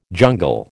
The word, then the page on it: jungle: American English